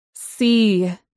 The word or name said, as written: sea